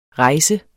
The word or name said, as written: rejsende